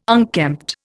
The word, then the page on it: unkempt: Indian